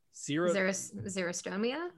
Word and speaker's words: xerostomia: They also note that screening for dry mouth, or how did you say that? – Xerostomia? – Yeah, I write this stuff down.